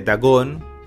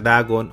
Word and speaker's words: Dagon: Estoy hablando de Dagon, esa es su pronunciación, la preferible ¿no? en, lugar de Dagon o Dagon, de Lovecraft.